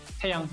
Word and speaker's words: Taeyang: Luego siempre me confundo como con la sílaba tónica porque podría ser Taeyang. – Taeyang, ajá, Taeyang. – Taeyang. Sí, pronuncias bien, Taeyang.